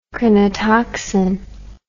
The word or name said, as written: crinotoxin